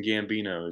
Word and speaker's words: Gambino: Justin Gambino is the name, and you nailed it.